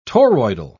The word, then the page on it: toroidal: British English